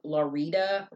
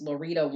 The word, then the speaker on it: Lauretta: Her father remarried a woman by the name of, and I think I'm pronouncing this right, it's spelled L-A-U-R-I-T-T-A. He remarried a woman, or I'm sorry, L-A-U-R-E-T-T-A is how it's spelled on her gravestone. He remarried a woman named Larita Ross, maiden name Ross, and she died in 1921.